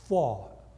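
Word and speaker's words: Pfau: 她的名字是 Rose Fall， P 不要发音的。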